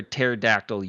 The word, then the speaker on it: pterodactyl: Why can't you hear a pterodactyl use the bathroom? – P is silent. – Because the P is silent.